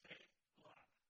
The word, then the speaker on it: Selah: But then, the next word that we read is the word Selah. Pronounced Selah.